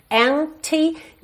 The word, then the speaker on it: empty: Empty, não se preocupe em pronunciar esse P, ok? Não precisa dizer empty, empty. Já fechou aqui o lábio no M? Já tá feito P, ok?